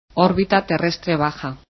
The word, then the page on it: leo: Spanish